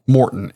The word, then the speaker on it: Maurten: Morton, right? – Morton, M-A-U-R-T-E-N. – Yeah, even though I'm saying it probably wrong. – No, that's how she pronounced it.